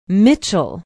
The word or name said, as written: mitchell